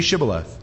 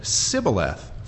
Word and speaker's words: Shibboleth: Say Shibboleth, and he said Sibboleth, for he could not frame to pronounce it right. See, they have an accent. The Ephraimites, they can't say sh. They say s when they're supposed to say sh.